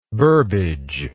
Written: burbidge

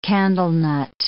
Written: candlenut

